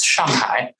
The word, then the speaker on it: Shanghai: Both sides of my family are originally from the Shanghai area, or if pronounced in Mandarin, it's Shanghai, which means on the sea.